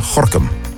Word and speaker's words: Gorinchem: Net als Gorkum, waar je Gorinchem schrijft.